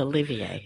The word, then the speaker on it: Olivier: Or Olivier Martinez. – Oh, it is Olivier. – Well, it's the way it's spelled. O-L-I-V-I-E-R. – Olivier Martinez. – It's French.